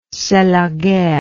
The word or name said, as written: c'est la guerre